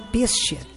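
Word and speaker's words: pistia (πίστις): A fé é uma palavra de origem grega que se pronuncia pistia, que indica acreditar.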